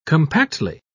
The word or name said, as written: compactly